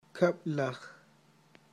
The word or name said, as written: kab'laj